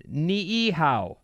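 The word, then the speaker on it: ni'ihau (Niʻihau): It's pronounced ni'ihau.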